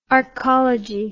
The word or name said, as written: archology